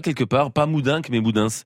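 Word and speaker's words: Moudens: C'est un nom qui se rencontre aussi sous la forme Moudin, M-O-U-D-E-N-S à la fin. – Ah ben là, on prononce tout, on dit Moudins.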